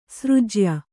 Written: ಸರಜ್ಯಾ

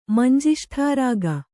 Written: ಮಂಜಿಷ್ಠಾರಾಗ

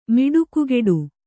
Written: ಮಿಡುಕುಗೆಡು

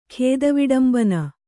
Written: ಖೇದವಿಡಂಬನ

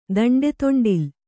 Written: ದಂಡೆದೊಂಡಿಲ್

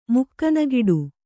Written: ಮುಕ್ಕನಗಿಡು

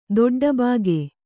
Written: ದೊಡ್ಡಬಾಗೆ

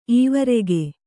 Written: ಈವರೆಗೆ

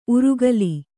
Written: ಊರೆಗೋಲು

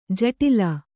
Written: ಜಟಿಲ

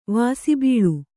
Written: ವಾಸಿಬೀಳು

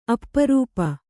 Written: ಅಪರೂಪ

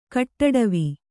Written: ಕಟ್ಟಡವಿ

